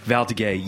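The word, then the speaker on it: Valdiguié: And he does like how do you pronounce it? Valdeguay. – Valdeguay. Yeah, that's like he's doing that stuff.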